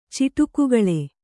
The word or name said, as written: ಚಿಟುಕುಗಳೆ